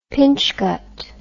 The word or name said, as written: pinchgut